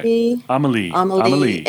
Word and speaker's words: Amélie: And this time it's Amélie. Did I say that right? – Amélie.